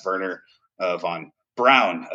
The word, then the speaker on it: Wernher von Braun: But for those of you that don't know, that's Wernher von Braun. Apparently, is how you pronounce it.